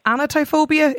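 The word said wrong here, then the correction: anatidaephobia: It's the irrational fear that somewhere a duck or a goose is watching you. – Yeah, anatodiaphobia, a fear of ducks watching you.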